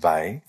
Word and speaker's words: Weil: She was her surname is spelt W-E-I-L, but yep. pronounced Weil.